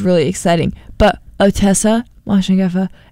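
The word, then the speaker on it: Moshfegh: But it's by Otessa Mosingoff? How would you say that? – Mosheffa. – Mosheffa. Otessa Mosheffa. – Okay. You nailed that on the second try.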